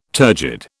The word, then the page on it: turgid: British English